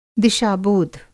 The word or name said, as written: दिशा-बोध